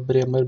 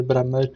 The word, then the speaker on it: Bremer: Eh, Ronaldo framme som vanligt när jag tycker mittbacken Bremer, uttalet Bremer, Bremer, eh, tycker jag gör väldigt dålig positionsspel, ja, försvarsspel och var, missade Ronaldo som markerade med minst en meter.